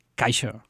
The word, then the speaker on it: Kaixo: Well, the best word, Kaixo, that means hello.